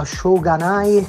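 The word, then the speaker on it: Shoganai (しょうがない): Allora, la parola si pronuncia Shoganai, che se non sbaglio ha lo stesso significato della parola Shkatanai.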